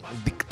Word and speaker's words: invicto: Invicto. Essa é a pronúncia. Invicto. – Você tem que fazer o C assim. Invicto.